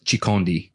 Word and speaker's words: Chaconde: But the correct pronunciation is Chaconde, mm-hmm.